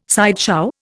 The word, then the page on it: sideshow: Indian